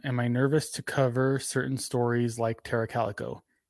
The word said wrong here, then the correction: Tara Calico: Uh, yeah, or Tara Calico. Sorry, I said it wrong.